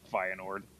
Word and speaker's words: Feyenoord: Feyenoord? – Yeah, Feyenoord. Oh, yeah. – There you go. – Wait, what? Warm. He's not going to friggin' Feyenoord.